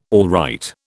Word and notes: alright: British English

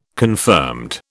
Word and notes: confirmed: British English

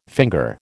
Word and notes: finger: American English

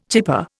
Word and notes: chipper: Indian

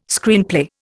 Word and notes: Screenplay: Indian

